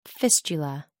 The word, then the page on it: fistula: American English